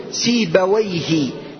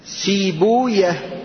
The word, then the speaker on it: سيبويه: قد عرفنا كلام النحاة واللغة، كلام اللغويين يقولون سيبويه، لكن المحدثون كيف ينطقونها؟ ها عندك؟ لا، كيف يقولون؟ سيبويا، نفطويا، مردويا، وهكذا. يعني يسكنون، يضمون ما قبل الواو، ما قبل الواو يضمون ويسكنون الواو ويفتحون الياء. سيبويا هذه يعني هذا هو نطق المحدثين لها.